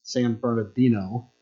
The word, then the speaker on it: San Bernardino: The world's first McDonald's is in St. Bernardino, California. And there was one restaurant serving one community in San Bernardino, California. San Bernardino, I'll say it right.